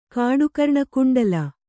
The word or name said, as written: ಕಾಡುಕರ್ಣಕುಂಡಲ